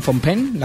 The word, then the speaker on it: Phnom Penh: Bueno, pues esto, como digo, ha sido en Phnom Penh, la capital de Camboya.